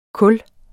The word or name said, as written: kul